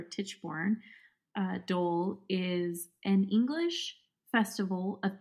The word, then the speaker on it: Tichborne Dole: So Tichborne, new word, dole, like D-O-L-E, like pineapple.